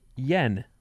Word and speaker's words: Jan: My husband's name is Jan, pronounced Yen.